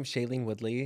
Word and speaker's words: Shailene Woodley: Shailene Woodley? – Yeah.